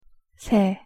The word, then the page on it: 새: Korean